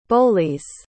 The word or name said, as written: bollies